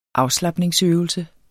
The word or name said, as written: afslapningsøvelse